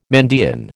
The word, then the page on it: Mandaean: American English